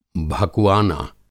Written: भकुआना

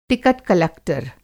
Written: टिकट-कलक्टर

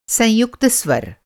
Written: संयुक्त स्वर

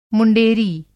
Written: मुँडेरी

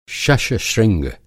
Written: शश-श्रृंग

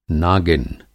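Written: नागिन

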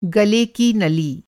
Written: नली

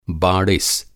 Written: बाड़िस

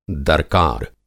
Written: दर्कार

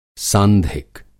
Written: सांधिक

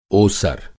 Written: ओसर

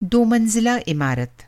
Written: मंज़िला

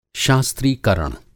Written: शास्त्रीकरण

